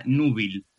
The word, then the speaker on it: núbil: la palabra núbil, eh, con acento en la U y B.